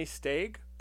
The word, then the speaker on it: Jeremy Steig: So the flute solos alone by the Pied Piper were played by Jeremy Steig, Steig. Is that how you would say his name? – Yes, I think so.